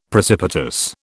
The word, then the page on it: precipitous: British English